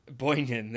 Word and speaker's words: Boynton: I feel like they need to, and I feel like that guy's going to be either Devontae Mays or Joel, I don't know how to say this name, Boynton. – Boynton, I think. – Boynton, there you go.